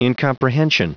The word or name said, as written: incomprehension